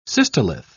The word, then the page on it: cystolith: British English